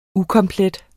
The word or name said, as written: ukomplet